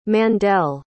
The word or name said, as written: Mandel